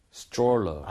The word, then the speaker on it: stroller: 자 이것도 스트롤러가 아니라 솔직히 생각해 보면 TR 모음이 거기 같이 들어가 있죠. – 맞아요. – 그러니까 스트롤러보다 어떻게 할 것 같아요? – Stroller. – 아, 그렇죠. Stroller. 이 모양 모으면서 치읓 비슷하게 좀 발음을 해주시면 좋겠고요.